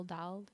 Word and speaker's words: Dahl: Roll Dahl? Is there a D on the Dahl? It's D-A-H-L. Dahl. Roll Dahl.